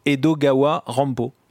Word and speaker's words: Edogawa Rampo: Edogawa Rampo. Quand on prononce Edgar Allan Poe en japonais, ça fait Edogawa Rampo.